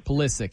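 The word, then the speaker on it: Pulisic: And they're going to look different with Polisky. Hopefully I said his name right. That's the name, right? – Polisky. Yeah. Yeah.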